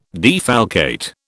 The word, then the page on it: defalcate: British English